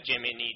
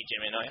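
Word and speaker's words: Gemini: جمینی جمینای هر کدوم که شما تلفظ میفرمایید همون، که این هم اگر یه اکانت گوگل داشته باشید میتونید با جمینی صحبت کنید.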